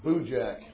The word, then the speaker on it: Boojack: And it's not Kojak or Bootrack. It's Boojack. It's pronounced B-O-O-Jack, Boojack.